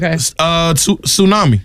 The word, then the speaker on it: tsunami: This has a silent letter in it. Tsunami. – Tsunami. – Tsunami. – Yeah. – Tsunami. – It sure does. – Now, some people might say tsunami. – Tsunami. – Tsunami. Somebody might say that. No. They'd be wrong.